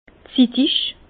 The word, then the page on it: zittig: Bas Rhin; Herrlisheim